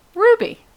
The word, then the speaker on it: RWBY: Your voice broke up when you said Ruby. – That. – Ruby. – Ruby. From the series Ruby, featuring the character of Ruby. – It's pronounced R-W-B-Y. – That's just the abbreviation for the team names.